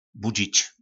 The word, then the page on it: Budzic: Po našimu